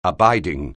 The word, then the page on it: abiding: anglais